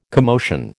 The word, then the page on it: commotion: American English